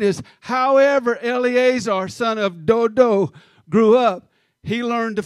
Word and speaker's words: Dodo: Dodo. – That's the pronunciation of that on my app for that guy's name.